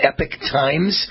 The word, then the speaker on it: Epoch Times: Some people call it Epoch Times, but the real name, the correct pronunciation is Epoch Times.